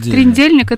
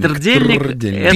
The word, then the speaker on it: трдельник: Я не понимаю тогда, как это произносится. – Трдельник. – Первая буква какая? – Т. Трдельник. А слово трдло.